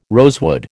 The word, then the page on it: rosewood: American English